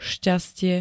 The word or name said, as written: šťastie